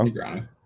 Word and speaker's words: Negreanu: Negreanu.